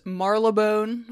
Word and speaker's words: Marylebone: In, I looked up how to pronounce this, Marylebone, a section of London's West End. I hope that's correct.